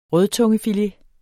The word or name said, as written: rødtungefilet